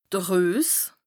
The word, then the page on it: de Rues: Bas Rhin